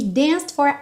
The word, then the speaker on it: danced: Sapphire moonlight, we danced for hours in the sand. Atenção aqui que o E do danced não é pronunciado, então nem tente falar danced for hours, ok? Danced for, danced for.